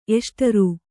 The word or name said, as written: ಎಷ್ಟರು